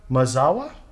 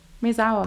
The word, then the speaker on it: Maezawa: And this time it was with Yusaka Maezawa. Is that how you say it? – That's right. – Okay. – Maezawa.